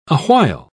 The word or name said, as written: awhile